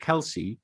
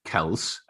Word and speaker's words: Kelce: That apparently the family still call it Kelce, but it is originally Kels, and some members of that family still pronounce it Kels, but they go by Kelce, if that clears it up anymore.